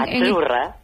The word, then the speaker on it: azzurra: Azzurra. – Azzurra. Mira, ¿ves? – Azzurra. Azzurra. Sí, como porque pizza, la Z no es la Z como es decimos en catalán tan sonora, no.